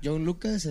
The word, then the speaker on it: Yng Lvcas: Exactamente, pero ¿cómo se pronuncia? John Lucas, así como joven Lucas.